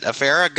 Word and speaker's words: Affera: Affera. Hopefully I pronounced that right. – You did. Yeah. – I did. Affera.